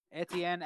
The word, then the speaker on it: Etienne: Etienne? How do you say his name, man? – Etienne at 23.